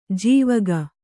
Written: ಜಾವಕ್

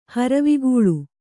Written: ಹರವಿಗೂಳು

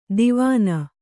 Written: ದಿವಾನಾ

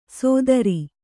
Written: ಸೋದರಿ

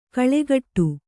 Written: ಕಳೆಗೊಡು

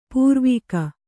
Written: ಪಾರ್ವಿಕೆ

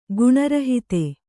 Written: ಗುಣರಹಿತ

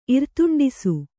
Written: ಇರ್ತುಂಡಿಸು

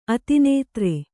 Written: ಅತಿನಿದ್ರೆ

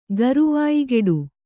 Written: ಗರುವಾಯಿಗೆಡು

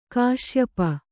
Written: ಕಾಶ್ಯಪಿ

